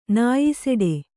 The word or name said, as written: ನಾಯಿಸೆಡೆ